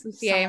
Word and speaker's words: Lussier: I would say it as Lussier. Some of the French name. – Lussier.